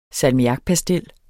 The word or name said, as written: salmiakpastil